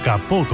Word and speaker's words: capot: El alemán tomó prestado ese caput en el siglo XVII del francés capot, hoy pronunciado capó concretamente,